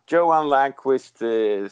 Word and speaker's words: Johan Langqvist: Did I get that right? Is that the right pronunciation? – Yeah, Johan Langqvist sounds pretty good in English. That's okay.